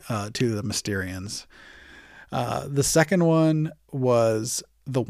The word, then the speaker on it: Mysterians: The Mysterians was probably the first one that I remember butchering horribly. I pronounced it the Mysterines for some reason.